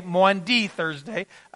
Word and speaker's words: Maundy Thursday: And so let me remind you, the Thursday night of this week, Maundy Thursday, all right, not Monday, Maundy Thursday. I always get that one wrong, all right, kind of like Illinois, right?